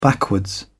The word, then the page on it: backwards: British English